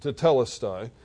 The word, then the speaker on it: tetelestai: The phrase in the original Greek of John chapter 19, verse 30 is tetelestai, properly pronounced, tetelestai, and it's rich in theological significance.